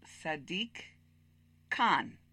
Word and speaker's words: Sadiq Khan: Or Sadiq, how do you say his name? That's who we're going to be talking about. – How do I read it? – Sadiq Khan.